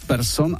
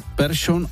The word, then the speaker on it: Persson: V případě výslovnosti sikavek převážila severská verze změkčení po písmenu R, tedy jména jako Persson a Larsson, teď to říkám, jak jsme to říkávali kdysi, už dnes ve Švédsku nikdo nevyslovuje ani v oficiálním projevu jinak než Persson a Larsson.